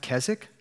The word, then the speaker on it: Keswick: It's spelled K-E-S-W-I-C-K. I feel like these people should learn to just kind of like sound things out, but it's fine. We're going to call it Keswick because that's what they say.